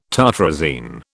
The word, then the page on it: tartrazine: British English